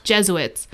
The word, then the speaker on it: Jesuits: She continued to say that the, uh, how do you say it? The Jesuits? Jesuits. – Jesuits.